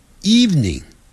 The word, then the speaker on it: evening: 只讲两个音节 evening eve evening evening evening evening evening 这样但不要念成 evening 是 evening 那个 v 的音很短 evening evening good evening 那是晚上好 good evening 好了不说这些了以后你会学到这个字我们先讲月亮月亮的发音跟中午 noon 很像只不过是他把这个 noon N-O-O-N 第一个 N 去掉，我们常常会念成 N-O-O-N。